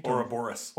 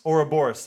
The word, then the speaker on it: Ouroboros: Ouroboros. – Ouroboros.